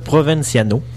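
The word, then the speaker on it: Provinciano: En fait, c'est le créateur du jeu, donnez-moi 2 secondes, je cherche le nom, Brian Provenciano.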